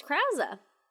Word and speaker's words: Krause: It's Krause. If you listen to Armchair Expert, he is on Armchair Expert with Dax Shepard, who plays Crosby, and he pronounces his name Peter Krause.